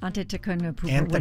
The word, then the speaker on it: Antetokounmpo: Giannis Antetokounmpo. – Is that it? Antetokounmpo.